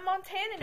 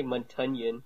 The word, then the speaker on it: Montanan: I'm Montanan. – Canadian. – I still want to say Montunyan. – Montunyan? You always pronounce things wrong.